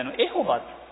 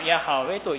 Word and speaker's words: ヤハウェ: エホバというふうに発音するんじゃないかと思われて、エホバエホバというふうに言われておりました。ただどうもエホバという発音はちょっとおかしいんじゃないか、その後の研究によって、まあ、ヤハウェというふうに発音するのが、まあ、正しいんじゃないかというふうに、まあ、今は言われている、まあ、そういう名前です。